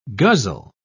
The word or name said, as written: guzzle